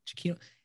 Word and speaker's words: Giacchino: is it Giacchino? – Giacchino. Yeah. – I'm glad he said it first because I would have never pronounced it that way.